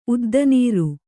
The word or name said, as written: ಉದ್ದನೀರು